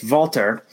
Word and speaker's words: Walter: It's Walter because he's German. So it's a V sound with a W.